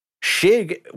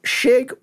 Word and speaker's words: Chig: So, but just for the record, though, Shig would actually probably be the proper pronunciation because it comes from Anton Chigurh is where right. My name origin comes from. So they actually probably pronounced it righter than every person that knows me does because people just went Chig because, you know, that's how it's spelled.